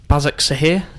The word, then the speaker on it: Başakşehir: Başakşehir, is that right pronunciation? – Sounds right.